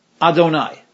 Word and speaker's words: Adonai: Kesheyazkir Hashem, when you mention God's name, which is written Yud-Heh Vav-Heh, but is pronounced Adonai, Aleph Dalet Nun Yud.